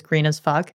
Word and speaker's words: Grnasfck: What they call themselves is spelled Grnasfck. And so I want to relish the amount of time I can use on the podcast to actually just pronounce their name, which is Green as Fuck.